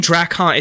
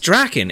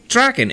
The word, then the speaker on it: Draken: They kept saying Drakkon. It's just Draken. It's Lord fucking Draken. It's Drakkon. – Draken. You can say Drakkon. It's fine. But don't enunciate it. You're putting the wrong fucking emphasis on the wrong syllable, and it's fine, and I hate you.